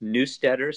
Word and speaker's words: Neustetter: Hi, my last name is pronounced Neustetter, so it's a little hard German name, but that's why people call me Dr. Randy.